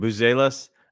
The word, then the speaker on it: Buzelis: I know how to say his last name. Buzelis.